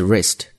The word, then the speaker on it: wrist: 注意最后一个单词 wrist。第一个 W 是不发音的，那么它的意思是一个名词啊，是手腕或者这个腕关节的意思。